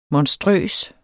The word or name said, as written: monstrøs